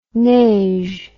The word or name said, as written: neige